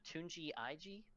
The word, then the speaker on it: Tunji Ige: But Tunji Ige, T-U-N-J-I space I-G-E, and he was born Olatunji Ige, and just shortened it to Tunji Ig or Tunji Ige.